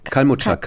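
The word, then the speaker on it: Kalmuczak: Kalmurczak? – Kalmurczak, Kalmurczak.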